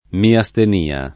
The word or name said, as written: miastenia